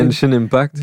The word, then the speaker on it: Genshin Impact: Le jeu de Chiching euh, Impact, là, je sais pas comment le prononcer. – Genshin Impact. Vas-y.